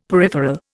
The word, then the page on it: peripheral: Indian